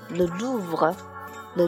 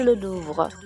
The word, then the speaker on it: le Louvre: 比如说，如果你要去卢浮宫，卢浮宫在法语里面要读作 “le Louvre”，“le Louvre”，它也是音译而来的。那你就可以说 “Excusez-moi，je cherche le Louvre”。